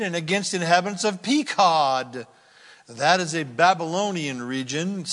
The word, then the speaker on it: Pekod: And in Hebrew you say Pekod, and it means to punish.